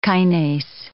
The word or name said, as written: kinase